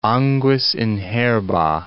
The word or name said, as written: anguis in herba